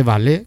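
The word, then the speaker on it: Valais: El cantón de Valle, que me lo estudié ahí para pronunciarlo bien, se dice como el baile.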